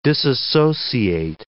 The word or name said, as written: disassociate